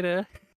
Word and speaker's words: Eyre: I was like, did you mean Jane Eyre? – Look, why is it not just spelled AIR? Come on, just spell it properly.